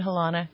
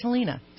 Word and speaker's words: Helena: You said Helena. I mean, I'm just saying it's Helena, so go ahead.